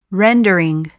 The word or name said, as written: rendering